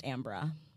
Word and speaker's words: Amber: My name is spelled Amber. It's pronounced Ambra.